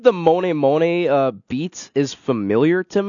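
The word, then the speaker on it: Mony: Moni. Moni, Moni. – Moni. – Moni, Moni. And then rhymes with pony.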